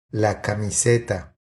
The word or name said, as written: La camiseta